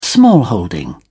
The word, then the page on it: smallholding: British English